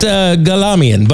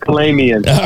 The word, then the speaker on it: Kalamian: And I have to say, you're the one that schooled me on the pronunciation name Kalamian, because I would have said it Galamian, but I'm going to say it the way you want. – That's the family name, Kalamian.